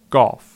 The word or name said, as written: Golf